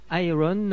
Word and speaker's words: Ayreon: Malgré un petit problème technique, et euh, je jure votre honneur que je suis innocent, donc euh, un extrait du dernier Iron, euh, pas euh, ça s'écrit A-Y-R-E-O-N, The Dream Sequence.